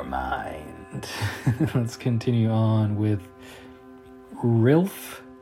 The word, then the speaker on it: RiLF: I believe that's how it's pronounced. It's capital R, lowercase I, capital L, capital F, in the true style of Japanese band names with specific capitalization. So I believe it's pronounced Rilf.